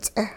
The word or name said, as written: ts'eh